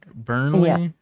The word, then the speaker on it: Burnley: Am I saying it right? Burnley? Yeah. Okay.